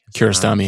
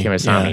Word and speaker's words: Kiarostami: It's in the, can never say his name, Abbas Kiarostami. – Kiarostami, yeah. Sure.